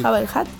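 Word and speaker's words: Jabba the Hutt: Jabba el Hutt.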